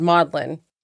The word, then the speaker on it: Magdalene: And actually that was a thread on Reddit because people who know, who read the comic book, pronounce it Magdalene because that's what it says. – Right. – And apparently in the 15th century, the English called Mary Magdalene, Saint Mary Mondelin, kind of like Madeline in French.